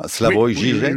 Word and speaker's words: Slavoj Žižek: Slavoj Žižek, bonjour. – Bonjour. – C'est comme ça qu'il faut dire, Slavoj Žižek.